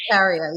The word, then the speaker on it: urticaria: Growing up, I also suffered with chronic hives where I always broke out in hives or what they call urticaria. I think I'm saying it correctly. – Yes, urticaria, yes.